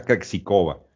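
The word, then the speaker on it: Krejčíková: Y el otro partido que vi fue el de Krejcikova contra Muguruza, acuérdense que se pronuncia Krejcikova.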